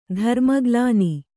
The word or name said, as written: ಧರ್ಮಗ್ಲಾನಿ